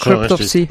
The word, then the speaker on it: Cryptopsy: Wir machen mit, jetzt spreche ich es bestimmt falsch aus, weil ich es immer falsch ausspreche, Cryptopsy. – Ja, das auch gesagt, Cryptopsy.